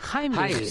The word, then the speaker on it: Heimlich: La siguiente fase sería la famosa maniobra de Heimlich. Creo que se pronuncia, es alemán. – Heimlich, sí.